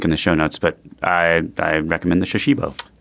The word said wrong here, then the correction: Shashibo: It's spelled Shashibo.